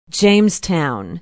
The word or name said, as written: jamestown